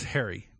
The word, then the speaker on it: Hari: I pronounced it Hari for years, and then someone, I said it out loud at a con once, and I got looks from around there. I'm like, what? It's Harry.